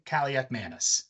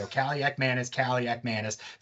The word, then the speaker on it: Kaliakmanis: And lastly, look, I like Athan Kaliakmanis. One, A plus for the pronunciation. I practiced it three awesome. Times before the show. Kaliakmanis. Thank you to Andy Staples for setting me straight on that.